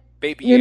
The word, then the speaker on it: Babierge: So it's like baby plus concierge. Uh-huh. So it's BABIERGE. Uh-huh. And baby-ierge.